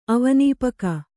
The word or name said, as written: ಅವನೀಪಕ